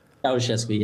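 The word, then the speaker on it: Ceaușescu: It was, the guy was Ceausescu, is that right? Is that how you say his name, the leader back then? – Ceausescu, yeah.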